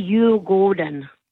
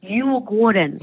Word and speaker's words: Djurgården: Now, that would be Djurgården, by the way. I'm just going to give you a bit of a pronunciation correction. Djurgården.